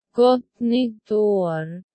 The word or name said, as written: gott nytt ar